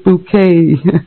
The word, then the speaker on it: Bucket: Yeah, her name is Bucket, right? Hyacinth Bucket, but she pronounces it bouquet.